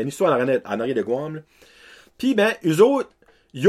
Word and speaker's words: Guam: Guam, euh, je le dis bien, Guam. Guam, pas Guam. Guam. G-U-A-M qui est une petite archipel, c'est une île dans le fond qui euh, appartient comme à, plein de pays.